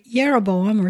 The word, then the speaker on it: Jeroboam: And Jeroboam is probably Yeroboam or something like that.